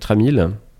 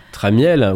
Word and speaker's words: Tramiel: Les Atari étaient parfois appelés les Mac du pauvre, ou même Jackintosh, en référence à son initiateur Jack Tramiel. – Jack Tramiel. – Tramiel, oui, Tramiel. – Alors ça dépend comment on prononce. Mais...